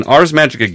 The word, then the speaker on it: Ars Magica: It was an Ars Magica game, pronunciation note, hard G as in geek, not gibbering.